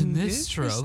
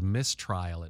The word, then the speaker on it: Mistral: It's spelled Mistral. And I thought it was Mistrial at first, but it's Mistral.